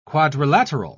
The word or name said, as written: quadrilateral